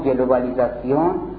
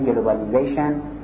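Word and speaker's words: globalization: این یزیزاسیونی که اینجا میذاریم از این به بعد ته سکولاریزاسیون، لیبرالیزاسیون، کپیتالیزاسیون، سوسیالیزاسیون، خب گلوبالیزاسیون اینجاست. این لفظ فرانسه است که میگیم مثلا گلوبالیزاسیون، سوسیالیزاسیون. تلفظ انگلیسیش میشه گلوبالیزیشن، سوسیالیزیشن، لیبرالیزیشن، مدرنیزیشن، اندیویژوالیزیشن و کپیتالیزیشن.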